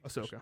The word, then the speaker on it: Ahsoka: Ahsoka. Is that how you say her name? – Ahsoka. – Ahsoka.